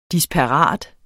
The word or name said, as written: DISPARATE